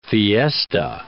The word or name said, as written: fiesta